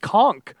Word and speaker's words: conch: A conch shell. – The conch. – It's pronounced conch. – That's not how they pronounced it in SpongeBob.